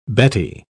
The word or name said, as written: Betty